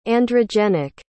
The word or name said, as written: androgenic